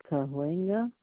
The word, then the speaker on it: Cahuenga: That's maybe Cahuenga? – Yes, I'm sorry.